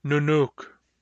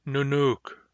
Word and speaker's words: nunuk: Nunuk. Nunuk. Don't get lazy and say nunuk. This is nunuk. Short u, long u. Nunuk.